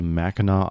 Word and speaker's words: Mackinac: It's called Mackinac Island. – Yeah. – Is it spelled Mackinac? – Correct. – Okay. Wow. That's the most non-phonetic name I've ever seen. – That's a Michigan pronunciation for you.